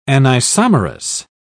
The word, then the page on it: anisomerous: British English